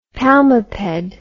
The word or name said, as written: palmiped